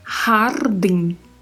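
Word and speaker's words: jardín: Nós temos três particularidades de pronúncia aí. A primeira é a letra J, que assim como no verbo rugar, aqui também tem o mesmo som. Ha, ha. A segunda particularidade é a letra R, que tem o som vibrato. Então eu tenho que falar har, har. E a terceira, que é o di. Em espanhol não existe a pronúncia do di, né? Como já comentamos. Hardin. Hardin. A pronúncia é din e com muita atenção, porque este N do final também não vai deixar a vogal ser muito aberta, né?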